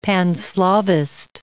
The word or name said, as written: Pan-Slavist